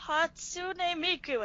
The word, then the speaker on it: Hatsune Miku: I was like, Hakuna Matata? – Hakuna Matata? And he was like, no, Hatsune Miku.